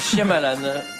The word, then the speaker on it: Shyamalan: Shyamalan. Je pense que c'est comme ça que ça se prononce.